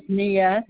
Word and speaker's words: Nejah: My name is Nia. That's spelled N-E-J-A-H. And the last name is Flower, S as in Frank, A-U-V as in Victor, E-R.